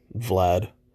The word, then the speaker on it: Vlad: No, Twitter is at only number one Vlad, VLAD, for whatever reason. People can't say my name, pronounce it Brad, Glad.